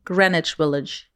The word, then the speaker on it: Greenwich Village: Ausgesprochen Greenwich Village.